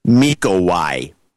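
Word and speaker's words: Mikołaj: His daughter Zuzana was born on the 20th of September this year, and his name is pronounced Mikowai, and I keep mispronouncing it, but now it's Mikowai.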